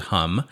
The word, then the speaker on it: Hum: It's spelled H-U-M, so you might pronounce it Hum. It is Hum, Croatia.